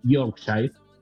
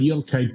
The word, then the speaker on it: Yorkshire: La región se llama el sur de Yorkshire. Es una palabra media complicada para pronunciar. – Yorkshire, como el perro. – Exacto, uh-huh. Ese mismo. Como el Yorkshire pudding, Yorkshire.